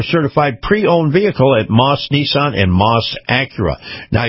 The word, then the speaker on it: Maus: Now, Moss is spelled M-A-U-S, Nissan.